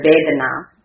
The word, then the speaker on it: vedana: Sanskrit word vedana, which we can go back to the garden again, not only the Garden of Eden, which it rhymes with.